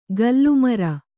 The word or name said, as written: ಗಲ್ಲುಮರ